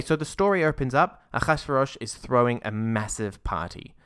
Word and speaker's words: Ahasuerus: All I know is the Hebrew name is Ahasuerus. – Ahasuerus. But no, I said that completely. I butchered that completely.